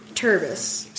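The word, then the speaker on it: Terbus: And so we flip the syllables. – Well, at first we were like, what's it backwards? And we're like, Rusteb or something? Yeah, that's not going to work. – No. So we flip the syllables. And it turns into Turbis.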